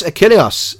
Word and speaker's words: Achilleos: I can pronounce his surname, so I'll let you pronounce it. – Chris Achilleos, yes. – Thank you, Achilleos, that's it.